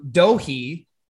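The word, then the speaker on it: Doege: My biggest annoyance is, so Jared Doge, I think it's D-O-E-G-E.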